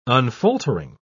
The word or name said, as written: unfaltering